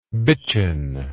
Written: bitchen

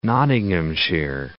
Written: Nottinghamshire

